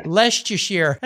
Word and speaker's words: Leicestershire: He lives in Leicestershire. Am I saying that right? – Leicestershire. – That's like Worcestershire sauce, you know? – Yeah, that kind of thing.